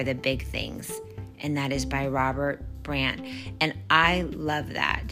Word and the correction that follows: Brault: Did I say that right? Robert Brault. I am so sorry. Robert Brault.